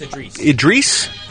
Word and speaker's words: Idris: I believe it's Idris.